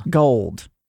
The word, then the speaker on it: Tracy Gold: No, it's Tracy Gold. – Tracy Gould. – Gold. – Well, no, she has, like, a little symbiote in her stomach. They pronounce it Gold. – It's just Gold.